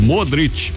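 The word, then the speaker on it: Modrić: o sobrenome terminado em IC, que se pronuncia IT, né? Que significa filho de. Então, sei lá, aquele, o Modric, por exemplo, é filho de Modr.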